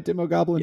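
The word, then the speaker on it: Demogoblin: Demo Goblin? – Yep.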